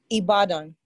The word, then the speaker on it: Ibadan: Ibadan? – Ibadan.